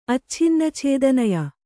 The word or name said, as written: ಅಚ್ಛಿನ್ನಛೇದನಯ